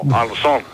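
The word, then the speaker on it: Ansón: Anson, Anson, con acento en la O, Anson.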